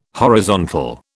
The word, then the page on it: horizontal: British English